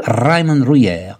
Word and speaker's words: Raymond Ruyer: curato l'antologia dei testi di Raymond Dreyer e poi La superficie... La pronuncia esatta è esattamente? Raymond Ruyer.